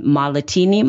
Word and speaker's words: Mahlatini: And then Malatini, my last name, MAHLATINI.